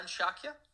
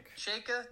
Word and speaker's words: Chayka: Arizona's John Shakya. – Chayka.